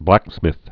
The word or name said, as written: blacksmith